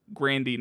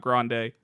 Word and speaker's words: Grande: And Ariana Grande said it's pronounced Grandy, not Grande, and then Christy Teigen said it's pronounced Teigen, not Teigen.